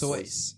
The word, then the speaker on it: Seuss: Which I've been told is actually the way he wanted it pronounced. – Sus. Really? I thought it was Sus. – Soyce.